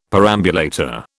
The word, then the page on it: perambulator: British English